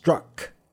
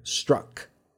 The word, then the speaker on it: Strzok: His name has somewhat of a peculiar spelling, Strzok, but it is pronounced Strzok with a U. Almost like a U sounding, Peter Strzok.